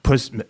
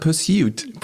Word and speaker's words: Pursuit: Pursuit. – Also es klingt jetzt genau wie bei mir. – Nee, Sie haben gesagt Pursuit. – Pursuit. Pursuit. – Ja, gut, also mehr nach Persil als Pursuit.